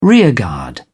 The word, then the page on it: rearguard: British English